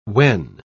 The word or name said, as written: wen